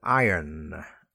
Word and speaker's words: iron: So, one exception is the word iron. Iron. Yeah, the R is very soft. Eh? Iron. It's almost like I-O-N, not I-R-O-N.